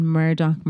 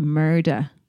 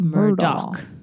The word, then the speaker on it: Murdaugh: Well, I'm saying Murdoch murders now because I was calling it the Murda murders. It's spelt M-U-R-D-A-U-G-H. – Mm-hmm. – But then I've been listening to podcasts in that sense and they say, it's Murdoch.